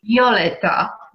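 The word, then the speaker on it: Wioletta: Violetta, do you say it with a V? Because you spell it with a W, and I just want to make sure I'm saying that correctly.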